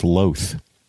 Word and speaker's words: loath: It says L-O-T-H, and so I had to make a split-second decision. Do I pronounce it loth, loath, or loath? I went with loath.